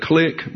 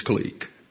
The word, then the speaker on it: clique: By the way, some people pronounce that click, but it's clique.